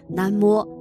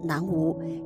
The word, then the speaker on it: 南无: 不仅在佛经中有，最常见的是在诸佛菩萨的名号之前，我们常说“ 南无阿弥陀佛”“ 南无观世音菩萨”“ 南无大愿地藏王菩萨” 等。“ 南无” 这两个字的正确读音是“ 南摸”，而不能读成“ 南无”，意思就是皈依、归命、依靠、依附的意思。